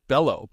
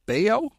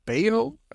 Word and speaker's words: Bello: I'm going to pronounce this guy's name wrong because I always pronounce it Bello, but it's Bayo, Brian Bayo of the Scott Bayo.